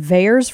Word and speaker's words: VAERS: Is it VAERS for short? – Yeah.